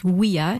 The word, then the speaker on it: via: Just as today, to connect cities, we have highways, from via, pronounced as via in classical Latin, and within a city, we have streets.